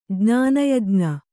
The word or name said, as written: ಜ್ಞಾನಯಜ್ಞ